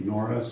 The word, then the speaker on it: Norris: My name is Barry Norris, spelled with an N.